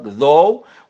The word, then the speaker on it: though: إذا كانت في آخر الكلام بقى حضراتكم إما أن لا تنطق though و although و through و أو لا أو تنطق فاء، enough tough rough laugh يعني يضحك cough يعني يسعل يكح وهكذا.